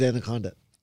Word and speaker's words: anaconda: How many syllables is anaconda? – Anaconda. – Did you say and? There's no D in anaconda.